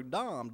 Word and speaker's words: Dam: Then you have Dam, and that's how it's pronounced in the Hebrew, Dam, D-A-M.